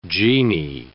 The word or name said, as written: jinni